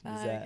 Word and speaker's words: Nazaire: Miles Nazaire. – I never know how to pronounce it. – Because it's French. Nazaire.